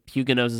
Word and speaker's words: Huguenots: discuss the Huguenots. Which is spelled, it's also pronounced Huguenots. It's the anglicized version, but Huguenots is the French, so we'll go with that.